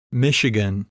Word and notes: Michigan: English

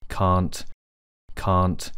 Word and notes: Cant: British English

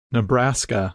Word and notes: Nebraska: English

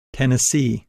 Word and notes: Tennessee: English

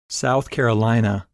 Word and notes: South Carolina: English

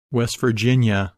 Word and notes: West Virginia: English

